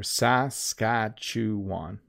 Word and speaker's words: Saskatchewan: If you think about it, it's Saskatchewan. If you say it slowly. So individually, it's a little easier. Saskatchewan.